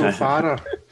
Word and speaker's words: Farah: And that's how you pronounce it, definitely. – I would say so, Farah. – Yeah. – Think of it like the sunglasses, the Wayfarer.